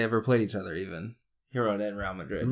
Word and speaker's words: Girona: Girona.